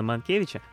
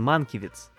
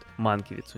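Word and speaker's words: Mankiewicz: Кстати, любопытная малюсенькая детальчика про а, Генриха Сенкевича, э, простите, про Германа Манкевича. А, в оригинале он не Манкевич, он Манкевиц. То есть и ударение по-другому стоит, и концовка фамилии по-другому произносится. Вот в Америке они так вот делают. Манкивиц у них.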